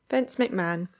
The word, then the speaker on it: Vince McMahon: Vince McMahon.